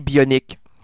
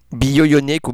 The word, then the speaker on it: bionique: Moi, j'ai l'oreille universelle, j'ai l'oreille, bon, aussi, il y a l'oreille bionique. Bio-ionique ou bionique, moi, je dis bionique parce que ça s'écrit bionique, là, pas bio-ionique.